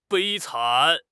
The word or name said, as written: 悲惨